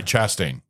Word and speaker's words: Chastain: Chastain. – Chastain. – Chastain. – Chastain. You were saying Chastain. Like Shasta. – All right, Chastain. – Yes. – There you go. Okay. Jessica Chastain.